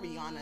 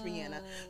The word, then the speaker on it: Rihanna: It's supposed to be Rihanna Fenty. It's not Rihanna, it's Rihanna. So because of this episode, I'm going to try and say it the proper way, sis, okay? Rihanna, because I know she's going to see this one day.